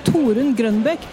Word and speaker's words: Torunn Grønbekk: The only thing is, like, if you're trying to say my name like the Norwegians would, okay, it would be Torunn Grønbekk.